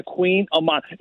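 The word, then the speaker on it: Kouign-amann: It's not spelled like king and queen. It's spelled with like a K and then like a Haman. It looks like Queen of Mon, and it's always funny hearing people like pronounce it, and then they always correct you. Oh, it's Queen of Mon.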